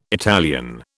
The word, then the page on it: Italian: British English